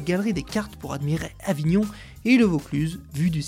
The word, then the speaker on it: Avignon: que l'on prononce Avignon.